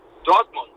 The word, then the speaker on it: Dortmund: Dortmund.